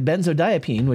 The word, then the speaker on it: benzodiazepine: Now, the most commonly used tranquilizing drug, uh, uh benzodiazepine, which we've talked about, activates GABA receptors in our brain. So that's interesting. We didn't we? It's called benzodiazepine, just for pronunciation.